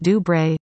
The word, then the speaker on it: Dubreuil: Dubreuil, pronounced Dubreuil, races that distance Sunday in Calgary.